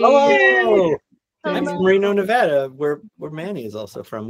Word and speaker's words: Nevada: We live in Sparks and we teach in Sparks, but nobody knows exactly where that is unless you're from Nevada, which is the correct way to say it.